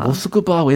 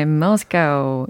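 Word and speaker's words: 모스크바 (Москва): 모스크바 왜 이렇게 발음이 다를까요? Moscow. – 네, Moscow. – I bet 부분. in Russian it's more like 모스크바.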